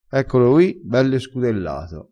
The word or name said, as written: scudella